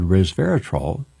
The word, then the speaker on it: resveratrol: if you add a plant-based compound called resveratrol, and you've heard me talk about res, R-E-S, vera, V-E-R-A, trol, T-R-O, resveratrol, three syllables, that you get it from grapes, you can also get it from berries, that it enables faster, more effective treatments.